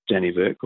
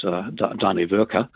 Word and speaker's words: Dannevirke: And of course, we have the Scandinavian early settlers that first came here and gave the town its name, Dannevirke, or we pronounce it wrong, it's Dannevirke.